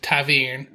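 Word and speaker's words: ta'veren: Taveren, E-H-N. So that's how they pronounce it, Taveren.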